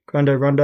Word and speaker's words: Grande Ronde: Is that how you say it? Grande Ronde? – Yeah, that works.